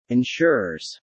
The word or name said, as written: insurers